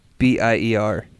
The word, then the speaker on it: Witbier: It's a blend of lime and grapefruit infused wit beer. – How do you pronounce that? Wit beer? – WITBIER.